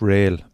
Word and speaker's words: Real: It's Rail. But it, okay. It's spelled Rail. R-E-A-L, but it's Rail.